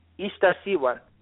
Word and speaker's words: Itzaccihuatl: Itzaccihuatl? – Okay, just the way it's spelled, it just kind of throws me off. – It's a word from the Nahuatl language. It's actually not Spanish.